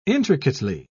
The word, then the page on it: intricately: British English